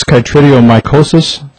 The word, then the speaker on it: chytridiomycosis: Is this chytridiomycosis? Am I saying that correctly? – Yes. Yes, okay. – That's correct. – Yeah. What apparently is being revealed is the fact that chytridiomycosis is,